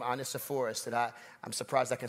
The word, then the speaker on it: Onesiphorus: Little computer lady goes, Onesiphorus.